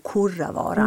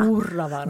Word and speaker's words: Kurravaara: Och sen så ska jag, så att inte du får brev från norrbottniska lyssnare, att Kurravaara uttalas som om du hade skrivit det med O, Kurravaara. – Kurravaara. – Bra, Kurravaara.